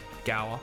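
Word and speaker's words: Gaur: All right, so for those playing at home, how do you pronounce that first word? – Gower.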